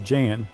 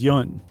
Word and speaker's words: Jan: I'm glad you said that because for my whole life I've been saying, well, first I said Jan, and I grew up and I thought Jan. I did not know it was Yun. – Well, this is what, J-A-N in this letter said it's pronounced Y-U-N-N.